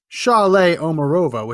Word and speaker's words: Saule Omarova: Saule Omarova, which I am told is how you pronounce her name, S A U L E.